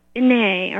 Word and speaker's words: bene: What they say on hashtag on YouTube, Andre Tetu Bene, or how's it pronounced? Bene, yeah, Italian.